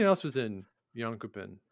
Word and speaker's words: Jönköping: The World Junior Curling Championships took place over in Jönköping, I believe, back in Sweden. Is that right, Scott? – It's pronounced Jönköping. – Oh, excuse me.